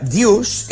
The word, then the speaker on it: deuce: En aquest cas, a la corrupció d'una paraula anglesa que identifica el 40-40, que és, s'escriu D-U-C, com bé deies, D-E-U-C-E, però es pronuncia deuce.